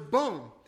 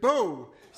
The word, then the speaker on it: bon: And then I tried to say that her husband was bon, but I pronounced it beau, so that what I actually said was that her husband was handsome.